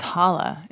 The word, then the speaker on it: Hala: It's Hala. – Hala. – Like as I called it, Hala. That's how she's pronounced.